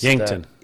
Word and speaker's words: Yankton: We have the Yankton. I don't know how to pronounce Yankton.